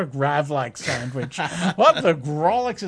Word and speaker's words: Grawlix: And you can get a copy, an ebook version of what the Gravlax is that. I said it wrong, didn't I? You know what I think? It's a Grawlix.